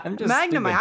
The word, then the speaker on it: Magnemite: Magnemite. Magnemite. I'm saying it right. – Yeah, you are.